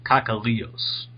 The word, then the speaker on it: Kakalios: And then one I got today, I'm going to butcher this name, but it's James Kakalos or something like that. Is that how you pronounce it? – I think it's James Kakalios.